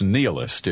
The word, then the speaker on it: nihilist: I think it's nihilist is the term is the way most people pronounce right.